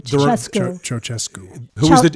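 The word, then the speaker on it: Ceaușescu: I think of Ceausescu. – Yes. – Am I pronouncing that properly? – Ceausescu.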